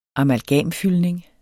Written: amalgamfyldning

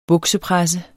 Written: buksepresse

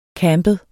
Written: campet

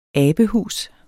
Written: abehus

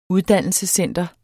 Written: UDDANNELSESCENTER

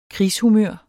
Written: krigshumør

